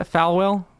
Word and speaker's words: Falwell: Well, or he's like Jerry Falwell and like really good at getting donations from the chickens, you know? Was that right? Did I say his name right? – Falwell.